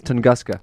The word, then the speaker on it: Tunguska: I believe that is Tunguska.